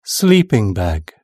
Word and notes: sleeping bag: British English

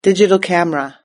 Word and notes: digital camera: British English

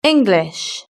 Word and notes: english: British English

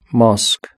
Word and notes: mosque: British English

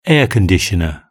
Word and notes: air conditioner: British English

